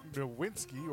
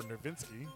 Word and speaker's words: Nowitzki: Moving along to another NBA star making a big splash, Dirk Nowitzki, or Nowitzki, depending on how you pronounce it.